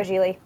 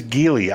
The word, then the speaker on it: Geely: I'm going to go Geely. They seem to be taking over the world. – By the way, it's Geely. I keep saying it wrong. It's Geely.